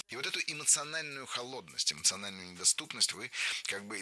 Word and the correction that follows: холодность: Холодность. Неверно. Правильно, холодность. Ударение в этом слове такое же, что и в исходном существительном. Холод, холодность. Легко запомнить.